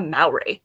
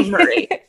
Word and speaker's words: Murray: It is no, it's Maura Murray. – It's Murray. I always want to say Maury. – Why do you want to say Mallory? It's spelt Murray.